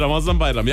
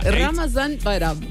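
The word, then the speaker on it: Ramazan Bayramı: Ramazan Bayram, ja. – Ramazan Bayram.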